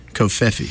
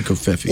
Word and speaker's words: covfefe: That's how he pronounced it. You know, he left everyone in a quandary, not knowing how to pronounce Covfefe. But then one day he pronounced it on tape, on a video. He said, Covfefe. Covfefe. So that's the official pronunciation, Covfefe. – Little Marco. L-I-D-D-L-E.